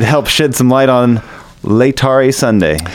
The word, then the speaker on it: Laetare: Another thing we have coming up this Sunday is Laetare Sunday. Am I pronouncing that correctly? – Laetare. – Okay.